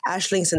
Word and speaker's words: Aisling: Ashling's an Irish name, which means dream, and their logo combines infinity symbols to describe their aspiration towards a limitless dream. Although spelled as A-I-S-L-I-N-G, it's actually pronounced as Ashling.